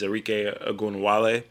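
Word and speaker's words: Arike Ogunbowale: Is Arike Ogunwale. Might have said her name wrong. – But no, I actually think you said it right.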